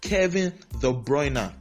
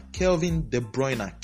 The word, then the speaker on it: Kevin De Bruyne: And then he came out to say his name is pronounced Kevin De Bruyne. Kelvin De Bruyne.